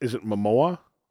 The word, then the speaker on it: Momoa: Is it Momoa? – Yeah, you're right.